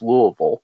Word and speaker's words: Louisville: The largest city in Kentucky is Louisville, which is where I'm from.